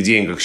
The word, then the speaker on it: деньгах: И совершенно правильно пишет Михаил Абрамович, и другие словари тоже так пишут, что в пословице «не в деньгах счастье», и в названии пьесы Островского «Бешеные деньги» в «Бешеных деньгах» здесь сохраняется ударение на первом слоге, как это было когда-то. Такой вариант был раньше, но сейчас э, он считается устаревшим.